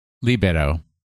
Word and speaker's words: Libero: Scorbunny will know Libero. Is that how you say it? – Libero, yeah.